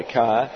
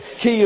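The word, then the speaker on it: key (χ): Some people call it a chi. I call it a key. But key is the correct pronunciation of this Greek letter.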